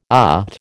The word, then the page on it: OT: American English